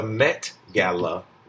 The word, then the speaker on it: Met Gala: We're going to talk about, did I say that right? The Met Gala? Met Gala, okay. Met. I keep putting a K on it. Damn. The Met Gala. There we go.